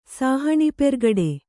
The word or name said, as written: ಸಾಹಣಿಪೆರ್ಗಡೆ